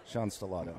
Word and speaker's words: Sean Stellato: Sean Stellato. – Mm-hmm. – Stellato. Got to say it right. – Yeah. – Because you mispronounce an Italian name, they're not as nice as when I mispronounce something Hispanic.